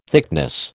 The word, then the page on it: thickness: American English